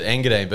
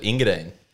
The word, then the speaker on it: Engadine: Uh, but she was like, I would pronounce it Engadine, but everyone there just calls it Ingadine.